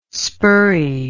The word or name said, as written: spurry